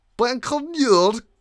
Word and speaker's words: Baikonur: So Sputnik space satellite was launched from Baikonur Cosmodrome in Kazakhstan. Is that good pronunciation? – Very good.